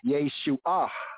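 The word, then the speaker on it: Yeshua (ישועה): In the Hebrew, we have Yeshua, Yeshua. Emphasize that H, the hey sound at the end. Yeshua, Yeshua, Yeshua.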